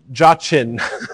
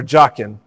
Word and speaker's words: Jachin: But in English, the transliteration sounds a little bit like this, Jachin or Jachin, all right?